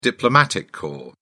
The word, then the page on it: diplomatic corps: British English